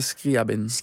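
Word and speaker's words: Scriabin (Скрябин): Scriabin zag ik er ook tussen staan. Zeg ik dat goed? Of is dat? – Ja, Alexander Scriabin.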